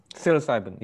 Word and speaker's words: psilocybin: Even now, somebody like, from a very secular perspective, Christoph Koch was talking about, I can never pronounce that drug, psilocytin or something. – Psilocybin, yeah.